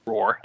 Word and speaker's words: Roer: It's R-O-E-R. How would you pronounce that? – Yeah, Roer. – Roer, okay. it's Roer like a lion, but with an E instead of an A.